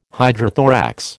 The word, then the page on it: hydrothorax: American English